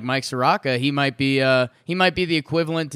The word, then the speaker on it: Soroka: Looks, according to Baseball Reference, it's pronounced Soroka. – Soroka.